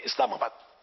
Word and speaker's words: اسلام آباد: اب جب بعض لوگ اسلام آباد حیدر آباد تو یہ تھوڑا تکلف کر رہے, ایسا نہیں حیدرآباد بولو, مد بولنے کی ضرورت نہیں ہے, اسلام آباد بولنے کی ضرورت نہیں ہے, اسلام آباد صحیح تلفظ بھی اسلام آباد سمجھے, کہ یہ عربی قواعد کا نہیں ہے.